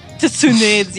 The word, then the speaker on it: Tsunade: Wait, hold on, I have to say this right. Hinata Hyuga and Tsunade's chest. – Tsunade's, yes.